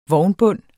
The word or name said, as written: vognbund